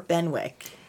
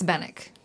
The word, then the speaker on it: Benwick: Is it Bennick or Benwick? – I think it's Bennick. – Okay. Because of course how it's spelled, you would think.